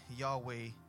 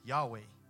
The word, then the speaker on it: Yahweh: But it was Y-H-W-H. There were no, in the Greek, Hebrew writing, there was no vowels between Y-H-W-H. We put the vowels in there so we can pronounce it in our English language, Yahweh. Okay? But the Hebrews, they didn't pronounce it that way. Okay? It wasn't so audible as it was breathe. It was way. That's how it's pronounced. Okay. Way. It's more of a breath.